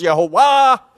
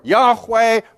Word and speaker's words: Yahweh: However that was pronounced, Yehovah or Yahweh or whatever, but it's definitely not the Lord.